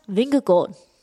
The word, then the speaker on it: Vingegaard: You spoke to Jonas Vingegaard of Jumbo Visma. Can you say his name better than I can? – Jonas Vingegaard. – That is much better.